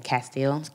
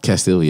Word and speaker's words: Castile: So specifically to Philando Castile, I can't say his name, Castile. – Castile, yes.